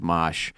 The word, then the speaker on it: Mache: It's the Mosh dude, his name is M-A-C-H-E, and it's pronounced Mosh.